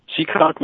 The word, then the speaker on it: Seekonk: Is that how that's pronounced? – Seekonk, Massachusetts. Motel 6.